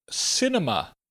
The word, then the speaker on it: cinema: Our next pronunciation tip, cinema. Cinema has an S sound where the C is. And we have a short I, a short E, and a short A, cinema.